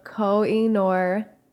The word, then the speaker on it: Koh-i-Noor: It's spelled like Koh-i-Noor. – It's probably something black. – Why do you say that? – N-O-I-R? – N-O-O-R. – Oh, I thought it was noir.